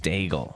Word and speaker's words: Daigle: Lauren Dangle, Daigle, Daigle. Lauren Daigle is uh, well done.